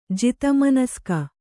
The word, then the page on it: ಜಿತಮನಸ್ಕ: jita manaska